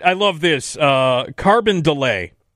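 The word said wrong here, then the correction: Carondelet: Carbondelet, as opposed to Carbondale. Carbondelet. – Yeah, that's like kind of the faux pretentious pronunciation. It's like calling Target Target.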